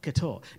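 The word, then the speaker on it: couture: And so he is asking, what is Ketor? That's how you pronounce it, Ketor.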